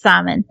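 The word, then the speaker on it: Szymon: I know that it's like Polish and it's like Zymon or something like it's S-Z-Y-M-O-N. But in English, anglicized, it's Simon. Simon.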